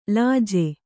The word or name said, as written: ಲಜ್ಜೆ